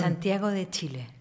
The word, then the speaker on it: Santiago de Chile: Santiago de Chile.